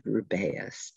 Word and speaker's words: Rubeus: Can we talk about how to say his first name? – Rubius? – I've heard it said Rubeus too. – I'm going to go with Rubius because that's what it says in the movies.